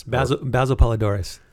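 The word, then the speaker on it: Basil Poledouris: But Basil Paul de Ruiz. – Basil Polidorus. – Thank you.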